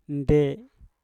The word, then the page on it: nde: Mpur